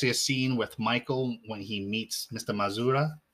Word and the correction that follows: Madrazo: Is that how you say his name? – Madrazo. – Madrazo, thank you.